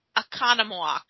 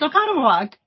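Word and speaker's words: Oconomowoc: I think it's Akonamwak. – Ooh, okay. – Oh, that's exactly it. Yeah, it's Okonomowoc.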